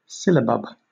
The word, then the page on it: syllabub: British English